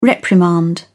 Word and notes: reprimand: British English

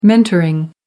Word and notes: mentoring: American English

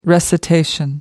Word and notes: recitation: American English

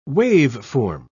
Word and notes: waveform: British English

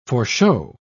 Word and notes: foreshow: British English

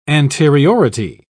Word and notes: anteriority: British English

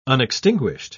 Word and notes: unextinguished: British English

